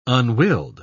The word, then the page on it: unwilled: British English